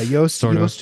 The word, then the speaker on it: Yoast: Yeah, Yoast just spelled it like toast, but with a Y. – Oh, really? – Even though his name is Danish, and it's spelled J-O-O-S-T, but people would just call him Juiced.